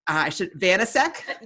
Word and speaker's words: Vanasek: Oh my gosh, Megan, Vanasek.